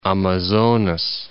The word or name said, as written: Amazonas